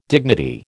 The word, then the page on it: dignity: American English